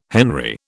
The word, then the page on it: henry: British English